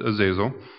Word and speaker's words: Azazel: As we talked, as I mentioned before, it comes from Hebrew word Azazel, or also some pronounce it Azazel.